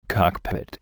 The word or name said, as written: cockpit